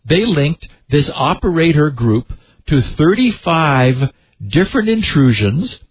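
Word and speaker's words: OPERA1ER: But a joint report published this week by security firm Group IB and Orange's CERT team, a French-speaking cyber group, tracked as, we'll pronounce them operator, although the T is a numeral one, so O-P-E-R-A, numeral one, E-R, also known as Common Raven or the Desktop Group.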